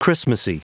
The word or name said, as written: christmasy